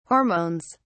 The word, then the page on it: hormones: British English